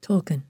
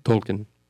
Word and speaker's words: Tolkien: It's for this reason that wise men, was a Tolkien, how do you say Tolkien? – Tolkien. – Tolkien.